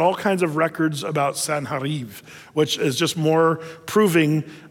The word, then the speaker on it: Sennacherib: One of the things that's largely lacking, by the way, in history is the message of, remember how we call him Sennacherib in the Bible? It's really more like Sanherib is the way you say his name.